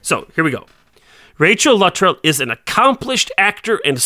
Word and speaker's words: Luttrell: I always thought of it as Luttrell with the accent on the second syllable, but I was listening to the commentary yesterday, and Rachel Luttrell and Carson Beckett, blanking on his name, Paul McGillion, there it is, we're doing the commentary, and she pronounced her name Luttrell, so I'm going to go with Luttrell.